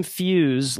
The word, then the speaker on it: Fuhs: And it's Jim Fuse, like a fuse box.